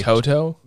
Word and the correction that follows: Todo: No. Todo. It's spelled T-O-U-D-O-U. – Oh, okay. Todo. – Yeah. And it's A-O-I.